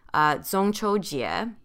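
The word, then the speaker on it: Zongchoujie (中秋节): In Mandarin, the Mid-Autumn Festival is pronounced Zongchoujie.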